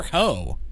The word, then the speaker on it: Heaux: She put out this album called Hotels, and it's spelled HEAUX, like Cajun. – For ho.